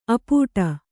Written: ಅಪ್ಪಟೆ

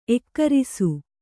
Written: ಏಕರಸ

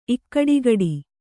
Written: ಇಕ್ಕಡಿಗಡಿ

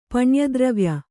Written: ಪಣ್ಯದ್ರವ್ಯ

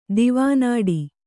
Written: ದಿವಾನಾಡಿ

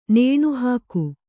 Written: ನೆನೆಹಾಕು

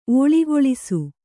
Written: ಓಳಿಗೊಳಿಸು

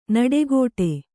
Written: ನುಡಿಗೊಡು

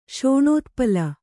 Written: ಶೋಣೋತ್ಪಲ

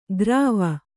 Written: ಗ್ರಾವ

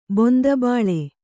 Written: ಬೊಂದಬಾಳೆ

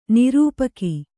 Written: ನಿರೂಪಕಿ

